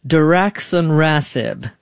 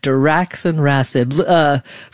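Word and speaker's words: daraxonrasib: Diraxonrasib. Did I say that correctly, doctor? – Yes, well done. – Okay, Diraxonrasib.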